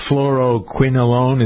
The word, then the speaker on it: fluoroquinolone: If you're just joining us, Ken and I were talking about antibiotics that could cause aortic aneurysms, the fluoroquinolone. Is that, am I pronouncing it correctly? – Good job. Right.